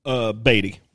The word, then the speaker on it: Beattie: Beattie. – The Beattie. – Like Warren Beattie.